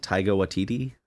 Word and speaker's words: Taika Waititi: Is it Taika Waititi or something like that? – Taika Waititi.